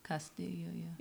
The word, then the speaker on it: Castillo: It includes Luis Castillo, how do you say that? Luis Castillo? – Castillo, Trevor Bauer, Sonny Gray, and of course Wade Miley.